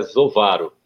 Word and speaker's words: Zovaro: Meu sobrenome, é, algumas pessoas erram, né? De origem italiana e é Zovaro.